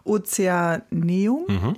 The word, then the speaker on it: Ozeaneum: Ozeaneum.